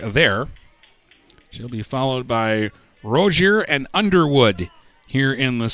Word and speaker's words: Roeser: It is spelled R-O-E-S-E-R, but pronounced Rozier.